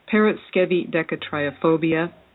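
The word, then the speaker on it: paraskevidekatriaphobia: When you take a look at that middle I after the V, it's not pronounced I or I. It's pronounced like a double E. So, Periscevi decatriophobia.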